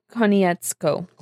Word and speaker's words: Konietzko: Michael DiMartino and Brian Konietzko. – Konietzko? – There's an R in there? – Whatever. Konietzko.